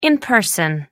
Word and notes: in person: American English

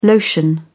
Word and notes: lotion: British English

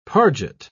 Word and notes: parget: British English

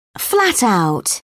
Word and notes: flat out: British English

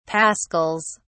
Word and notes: pascals: British English

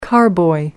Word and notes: carboy: American English

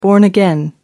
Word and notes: born again: American English

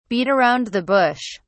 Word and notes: beat around the bush: British English

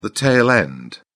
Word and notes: tail end: British English

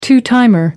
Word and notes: two timer: American English

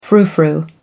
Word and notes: frou frou: American English

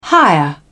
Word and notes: hire: British English

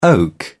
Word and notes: oak: British English

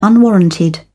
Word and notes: unwarranted: British English

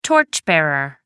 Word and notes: torch bearer: American English

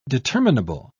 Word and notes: determinable: British English

